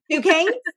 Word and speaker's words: Duquesne: I don't even know how to pronounce it. It's so impressive. – Duquesne? Yeah, okay. Duquesne. Got it.